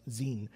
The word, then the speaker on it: Zin: It in Hebrew would be pronounced Zin, which is between Elim and Sinai on the 15th day of the second month after they had come out of Egypt.